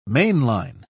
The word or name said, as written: mainline